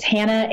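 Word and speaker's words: Hanna: My name is spelled a little bit different than most, but it's Hannah,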